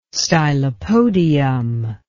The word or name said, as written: stylopodium